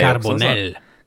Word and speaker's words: Carbonell: Han är Carbonell.